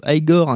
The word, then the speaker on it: Igor: Igor, qui se prononce Igor,